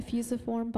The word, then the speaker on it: fusiform: Is their fusiform body, is that how you say it? – Mm-hmm. A fusiform body.